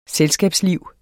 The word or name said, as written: selskabsliv